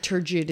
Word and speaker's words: turgid: And the last thing, I did find a definition for turgid. – Oh, yes. Turgid is how you say it. Yes. Okay. – Turgid. T-U-R-G-I-D.